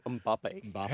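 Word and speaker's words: Mbappé: Kylian Mbappe.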